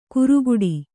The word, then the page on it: ಕುರುಗುಡಿ: kuruguḍi; Kannada